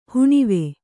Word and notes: ಹಣವು: haṇavu

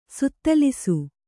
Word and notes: ಸುತ್ತಲಿಸು: suttalisu

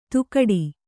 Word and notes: ತುಕಡಿ: tukaḍi

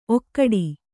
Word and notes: ಔಕಾಟ: Kannada

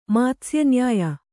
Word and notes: ಮಾತ್ಸ್ಯನ್ಯಾಯ: mātsya nyāya

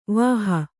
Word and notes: ವಾಹಿ: vāhi

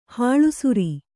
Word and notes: ಹಾಲುಸೋರೆ: hālu sōre

